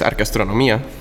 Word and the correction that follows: arqueoastronomía: Sí, sí, eh, sí arqueoastronomía. De hecho, eh, ahora estamos usando más el, término astronomía cultural.